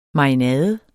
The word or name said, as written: marinade